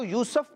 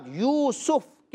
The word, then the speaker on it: یوسف: اچھا بچوں، اس کو بعد لوگ یوسف پڑھتے ہیں، سین کے اوپر زبر پڑھتے ہیں، تو یہ درست تلفظ نہیں ہے۔ اس کی درست پروناؤنسیشن کیا ہے؟ سین کے اوپر پیش کے ساتھ یوسف۔ کیا ہے؟